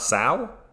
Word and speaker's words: sow: Yeah, sow, like rhymes with cow.